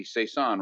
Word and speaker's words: Saison: And I started with Bespoke, which is their hoppy, Hudson Valley Brewery's hoppy Saison. Right, Mike? Did I say that right? – You got it, man.